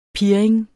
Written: pirring

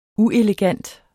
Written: uelegant

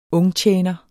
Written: UNGTJENER

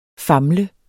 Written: famle

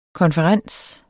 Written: konferens

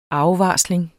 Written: afvarsling

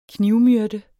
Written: knivmyrde

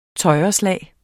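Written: tøjreslag

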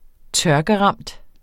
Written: tørkeramt

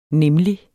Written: nemlig

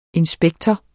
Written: inspektor

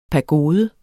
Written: pagode

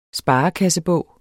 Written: sparekassebog

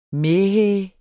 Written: mæhæ

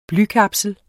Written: blykapsel

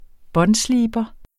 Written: båndsliber

